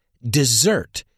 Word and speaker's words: dessert: Desert se pronuncia desert. No te líes con dessert, postre, que se pronuncia dessert.